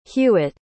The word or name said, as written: hughette